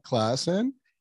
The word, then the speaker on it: Claassen: So Andrea Claassen, did I say it right? – Claassen, so yes.